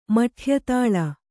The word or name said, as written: ಮಠ್ಯತಾಳ